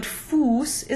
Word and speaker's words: Fuß: Kuss is short, but Fuß is long.